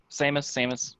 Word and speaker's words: Samus: So Brie Larson wants to play, am I saying the name right? Samus? – Yes. Samus.